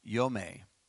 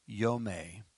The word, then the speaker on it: yowm: But Y-O-W-M, which is pronounced Yome, but you can verify it on 3117. Okay? Y-O-W-M, Yome.